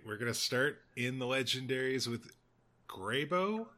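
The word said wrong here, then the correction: Greybough: I think it's Graybow.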